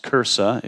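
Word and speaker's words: Kursi: It's pronounced Cursa if you happen to go to Israel. If you say Gersa, you'll end up probably in the West Bank or something.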